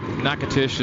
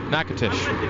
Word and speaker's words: Natchitoches: And you would not guess that Natchitoches is spelled the way it is. Let's tell you that right now. It looks like Natchitoches, and it's pronounced from people in those parts, Natchitoches.